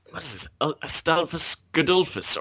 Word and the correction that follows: Gustavus Adolphus: or how do you say his name? How does his name go? – Gustavus. – Gustavus Adolphus. – Yeah, you nearly got it.